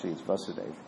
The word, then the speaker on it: Vasudeva: It's Vasudeva, it's the right pronunciation.